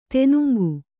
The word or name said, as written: ದಿನಾಂಕ